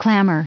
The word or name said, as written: clamor